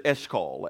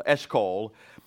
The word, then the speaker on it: Eshcol: We're going to talk about the Valley of Eshcol today, and that's how you pronounce it, Eshcol, Eshcol.